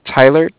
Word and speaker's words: Tyler: Yeah, my name is Tyler, T-Y-L-E-R.